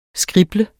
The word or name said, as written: SKRIBL